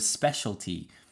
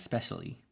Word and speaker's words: specialty: I can hear, for example, in my last sketch video that I failed to pronounce the T in the word specialty, and it comes out as specially in that writing prompt.